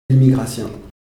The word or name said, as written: immigratien